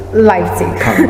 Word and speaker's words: Leipzig: From the University of Leipzig. – Leipzig.